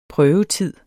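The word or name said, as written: prøvetid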